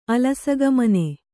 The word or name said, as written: ಅಲಸಗಮನೆ